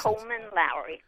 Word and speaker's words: Coleman Lowrie: Coleman Lowrie.